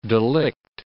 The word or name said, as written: delict